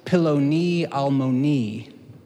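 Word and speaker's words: peloni almoni: This is really a phrase that's pronounced piloni almoni, or as a good American would say it, piloni almoni. Everybody just say piloni almoni.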